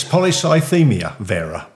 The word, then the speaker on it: polycythemia vera: And how do you pronounce it? – It's polycythemia vera.